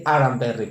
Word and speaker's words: Aramberri: El tema que les traigo el día de hoy, mire usted, es la casa de Aram, de Aram Berry, perdón, porque lo pronunciaba mal.